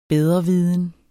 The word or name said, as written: bedreviden